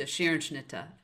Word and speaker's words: Scherenschnitte: She did decorative paper cutting, which in German pronounced to Scherenschnitte.